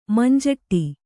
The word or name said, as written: ಮಂಜಿಟಿ